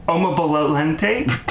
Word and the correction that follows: Omobolanle: Omobolalele. No, that's not it. Omobolanle. There we go. There's no T in there.